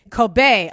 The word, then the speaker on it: Kobe: Kobe.